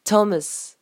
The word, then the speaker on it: Thomas: Pour le prénom, donc Thomas, que ce soit en nom de famille ou en prénom, prononcez toujours le S. Thomas.